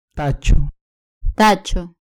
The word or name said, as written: tacho